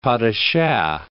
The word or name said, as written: pas de chat